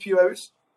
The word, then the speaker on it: Piraeus: So can I pronounce like that, Piraeus? – Yeah, sure. It's correct.